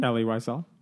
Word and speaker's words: Elie Wiesel: Elie Wiesel.